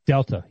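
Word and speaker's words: Deltha: Delta, yeah. – It was Delpha. – Pronounced Delta, though, dummy.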